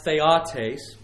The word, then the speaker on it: theotes: It's pronounced theotes, not that that really matters, but if you want to say it, that's how you say it.